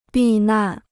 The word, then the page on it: 避难: bì nàn